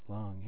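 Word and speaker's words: lung: Long air or wind, for instance, is not pronounced like the human organ, but with a U sound, as in the word scuba. So it's long.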